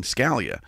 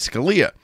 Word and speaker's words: Scalia: And of course, when he left, I was pronouncing my name Scalia because I was in New England and I wasn't traveled and people all would always say it. And then when I started traveling, people would see it in print and they said Scalia. And after the 50th time, I stopped correcting people and I went, well, they are pronouncing it the Italian pronunciation.